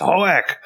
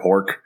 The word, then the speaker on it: Hoek: It wasn't one of the ones we watched, but there's definitely episodes where Ren corrects people. It's Hoek! But, like, they kept calling him, like, Commander Hork.